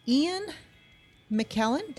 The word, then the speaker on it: Ian McKellen: Sir Ian McKellen, did I say his name correct? – Yeah, sounds okay.